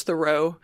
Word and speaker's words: The Row: which I had to play a video to make sure it's not pronounced The Row, because... – You never know. – I think what people really look to Trashy Divorces for is our keen sense of pronunciation. So anyway. – We have some real expertise. – It's The Row.